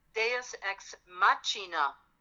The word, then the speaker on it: Machina: It's Deus Ex Machina. – Oh, so the C-I is pronounced like a C-H.